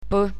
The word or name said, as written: p